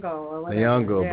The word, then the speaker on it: Nyong'o: Nyong'o.